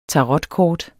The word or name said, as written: tarotkort